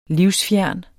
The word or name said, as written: livsfjern